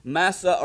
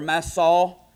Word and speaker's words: Massaw: So I looked the word up, and it is the word that is pronounced Masa or Masaw. M-A-S-S-A-W is how it's pronounced in the Scripture.